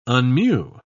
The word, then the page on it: unmew: British English